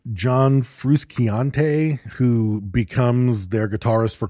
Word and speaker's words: Frusciante: I think it's just Frusciante is how you say that. I think Frusciante.